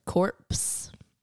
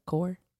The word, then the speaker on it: Corps: I want everyone to know that Logan said corpse instead of core. – The Peace Corps. – It's not pronounced corpse. It's just the Peace Corps. – And why is there a P in there? – I don't know.